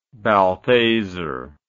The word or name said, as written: balthasar